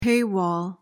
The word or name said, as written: paywall